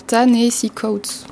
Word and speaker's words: Ta-Nehisi Coates: Alors, Ta-Nehisi Coates.